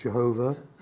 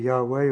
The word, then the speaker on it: Yahweh: As I've already said, well, by tradition, transliterated and pronounced as Jehovah. Much more accurately, it would be Yahweh, or if a Jewish person would ever bring themselves to say it, which most won't, Yahweh. Or we say Yahweh.